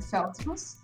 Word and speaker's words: Feldfus: Marília Mafé teu sobrenome eu não sei pronunciar. É Feld? Como é que pronuncia, perdão? – É Feld, mas é por isso mesmo que ele entrou, que entrou esse novo nome, né? Uma fé que eu tô assinando agora. – Uhum. – E é... Mas enfim, o, o sobrenome mesmo é Feldfus.